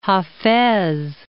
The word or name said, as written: Hafez